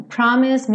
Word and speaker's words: promise: You promise me heaven, promise me heaven. Esse promise, também o E não é pronunciado.